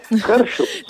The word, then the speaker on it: Herr Schultz: Hoy hablamos, que interpreta a Herr Schultz. No sé si lo he pronunciado bien. Hola, buenos días. – Hola, buenos días. Perfectamente. Herr Schultz.